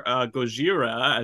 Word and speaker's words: Gojira: or Gojira, as it's like originally pronounced,